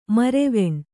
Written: ಮರವಣೆ

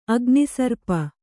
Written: ಅಗ್ನಿಸರ್ಪ

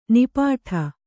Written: ನಿಪ್ಪಿಟ್ಟು

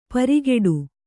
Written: ಪೆರ್ಗಟ್ಟ

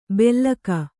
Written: ಬೆಲ್ಲಕ

